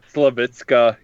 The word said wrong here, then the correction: Slavica: Jovan. – Slavica. – Oh, okay. Slavica.